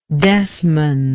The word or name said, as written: desman